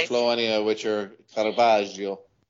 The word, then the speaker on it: Caravaggio: Is that not pronounced Caravaggio? – No, it's not. It's Caravaggio.